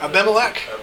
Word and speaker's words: Abimelech: Where Abraham, how do you pronounce his name? Abimelech. – Abimelech.